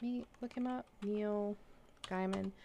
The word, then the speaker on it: Gaiman: Writer's name is Neil Gaiman.